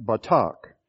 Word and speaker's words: batach: It's spelled in the English way of writing it, batach, in B-A-T-A-C-H.